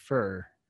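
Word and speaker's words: Foer: who that's F-O-E-R-R? F-O-E-R-R, I think is how it's spelled. And it's pronounced Furr. – But, there's a diphthong right there for you. There's a diphthong in there. It's not fo-er.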